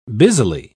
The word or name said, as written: busily